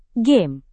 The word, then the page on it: game: Indonesian